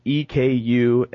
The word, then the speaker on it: Ekundu: But have you guys ever heard of Ikundu Cyclops? – No. – No? – How do you spell that? – It's Ekundu Cyclops.